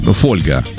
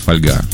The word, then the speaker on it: фольга: Раньше, кстати, говорили фольга вместо фольга.